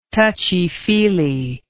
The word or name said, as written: touchy feely